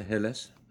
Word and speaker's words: Helles: Chuckanut Brewery had their Chuckanut Hells. – Yeah, the Helles. – Helles? – Helles.